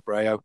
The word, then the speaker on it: Brehaut: I mean, the social media platforms that I use are Twitter, which is just my name, so at Josh Brehaut,